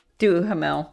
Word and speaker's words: Duhamel: Duha's not. It's Duham. – Duhasme. – Duhamel. – It's what? – Duhamel. – Duhamel. Yeah. Oh, gosh. Duhamel? Is that what you said? – Duhast. Duhast. Sorry. Duhamel.